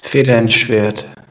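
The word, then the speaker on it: Feder & Schwert: But under the art house of Federn, am I pronouncing this right? Federn Schwarz? – Federn Schwert. – Yeah. Federn Schwert.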